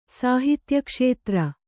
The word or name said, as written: ಸಾಹಿತ್ಯಕ್ಷೇತ್ರ